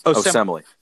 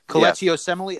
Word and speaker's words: Kelechi Osemele: Osemele. – Kelechi Osemele. Yeah. I think that's Kelechi Osemele.